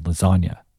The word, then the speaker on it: lasagna: Of course, Wayne adds his clever spin on this classic hip-hop trope by comparing the silence of G's to the silent G in the word lasagna. With the code of silence being associated with the Italian mafia, Wayne seems to have purposely chosen an Italian dish for a simile.